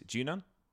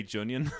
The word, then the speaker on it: Junon: Okay, so from there then we get I said this wrong for years. Junon. So we got Junon with Rufus. – What do you say? – I used to say Junion. – Junion. – Like an onion with a J, like, you know, with the Junion.